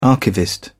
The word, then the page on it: archivist: British English